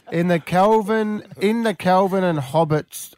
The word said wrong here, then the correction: Hobbes: what's that say? – I think it's Hobbes. I think it's Hobbes. – Oh dear. That's right.